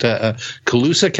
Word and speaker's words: Colusa: And this is the one that took place in, and I'm not sure if I'm going to pronounce this name, is it Colusa, California? – That's correct.